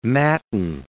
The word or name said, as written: matin